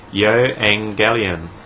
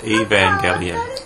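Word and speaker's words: ヨ エ ン ゲ リ オン (εὐαγγέλιον): The Greek word used in this passage for the gospel is ヨエンゲリオン。 – ギリシャ語で福音とはヨエンゲリオンと言います。 – Sometimes it's pronounced Evangelion.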